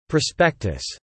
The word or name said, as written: prospectus